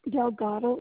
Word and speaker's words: Delgado: Yes, Delgado is the last name.